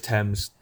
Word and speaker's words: Thames: Was it River Thames or River Thames? I don't know how to pronounce it. It's Thames.